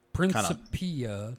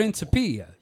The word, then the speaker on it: Principia: So according to Google, it's Principia. It does have the E. – Principia. Okay, Principia. Kinda. – Yeah, so stress on the third syllable. – Principia. You say it like Italian.